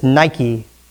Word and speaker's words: Nike: Now, does that sound familiar, that word Nike? How about if I said it like this, Nike, spelled the same, translating into English, Greek to English. That is where the brand Nike comes from, this Greek word Nike. Really specifically, it comes from Nike, the goddess of victory, who flew over battlefields crowning champions.